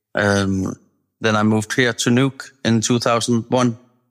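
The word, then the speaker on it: Nuuk: Pronounce for me the town. Is it Nook or is it Nook? – Yeah. Nook. – It is Nook.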